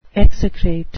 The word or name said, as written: execrate